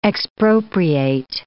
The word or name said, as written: expropriate